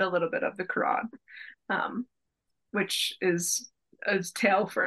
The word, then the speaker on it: Quran: Judaism is involved in there, and so is Islam, but I'm not as well versed because, of course, you know, the Quran, or am I saying that right? Yeah.